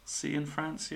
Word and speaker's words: Cianfrance: Well, a director I thought of was Derek Cianfrance. Is that how you say his name? – Cianfrance, yeah.